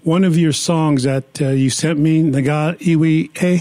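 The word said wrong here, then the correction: Ngā Iwi: It's called Na'iwi. It means tribe, and it's Maori.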